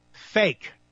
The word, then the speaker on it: PHEIC: Guess how it's pronounced? Fake.